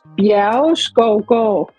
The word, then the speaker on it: Bjergeskovgård: It's Bjergeskovgård.